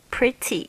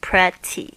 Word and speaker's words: pretty: Pretty, P-R-E-T-T-Y. 有很多同学呢，可能会照字面上会念成 pretty，其实不是 pretty，是 pretty。这个 e 的发音呢，是发这个小 i 的这个 it 的声音。